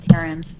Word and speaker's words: Caryn: It's spelled C A R Y N. Karen.